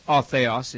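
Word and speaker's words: otheos (ὁ θεός): 1,300 times the word otheos in Greek, I'm using the modern Greek pronunciation, as to say the God, the one God, over 1,300 times refers to the Father.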